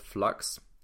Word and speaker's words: f.lux: The app is called Flux, F.Lux. So F.LUX. So that's how you spell it.